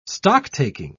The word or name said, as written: stocktaking